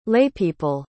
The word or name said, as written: laypeople